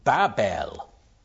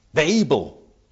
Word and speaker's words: Babel: We call it Babylon, three syllables, in English. The Hebrew word is just Babel, Babel.